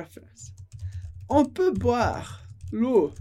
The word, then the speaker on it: l'eau: L'eau significa a água. Só esse som, l'eau, l'eau, porque as letras E, A, U sempre vai dar o som O.